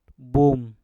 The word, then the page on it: bum: Mpur